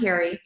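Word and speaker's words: Cari: So, and that's RUNCARI. So my name is spelled a little bit different, but Run Carrie.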